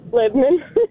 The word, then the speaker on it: Lebanon: You don't say Lebanon. You say Lebanon, right? – Lebanon.